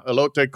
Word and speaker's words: elote: Alito. Alito. How did I say it? – Elote.